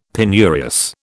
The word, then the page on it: penurious: British English